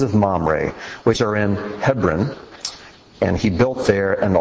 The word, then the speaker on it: Hebron: Hebron is how it's pronounced in Hebrew.